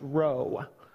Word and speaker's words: rho: In Greek, those letters are chi, and the P actually is pronounced rho.